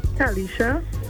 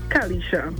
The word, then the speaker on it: Calisha: Kalisha. – What's it? – Kalisha. – Cal, spell that. – C-A-L-I-S-H-A. – C-A-L-I-S-H-A.